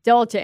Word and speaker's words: Dolce: Yeah, it's like a type of like brand that women have, Dulce Gabbana. – Mm-hmm. Yeah, Dolce and Gabbana.